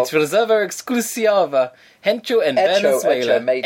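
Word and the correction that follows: Hecho: Echo. – Really? – Yeah, they don't pronounce the first echo. It means made.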